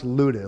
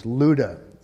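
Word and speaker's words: Lydda: That's pronounced Luda. It looks like Lydia. There's no I in there. Uh, the Y is like a U. And so it's Luda.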